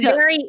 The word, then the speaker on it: Gadot: I never know how to, is her last name, is the T, do you say the T? – I think it's Gadot. Like, it's Gadot. Very faint. Like, you don't say Gadot.